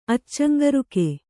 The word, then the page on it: ಅಚ್ಚಂಗರುಕೆ: kannada